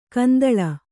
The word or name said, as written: ಕೆಂದಲೆ